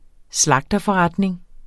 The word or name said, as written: slagterforretning